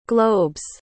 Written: globe's

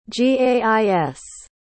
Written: gais

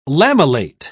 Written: lamellate

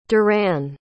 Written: duran

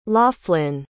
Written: loughlin

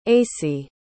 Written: acey

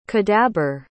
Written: cadabyr

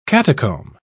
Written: catacomb

